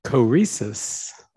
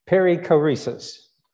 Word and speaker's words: Perichoresis: Peri, Perichoresis. – Very good. Very good. Perichoresis.